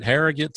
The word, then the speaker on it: Harrogate: You know, we were in the bustling metropolis of Hartsville, South Carolina, and now we're going to the state of Tennessee, to Harrogate, Tennessee. Am I saying that right? Harrogate, Tennessee? – Yes, sir.